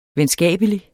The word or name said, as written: venskabelig